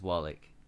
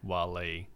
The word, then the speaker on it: Waalegh: Yes, and using the correct pronunciation as well. It's like you look at it the first time, and I used to say Wally. – Yeah, me too. – Because it is W-A-A-L-E-G-H.